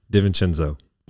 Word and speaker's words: DiVincenzo: Dante DiVincenzo? – DiVincenzo. – DiVincenzo? – He's Italian.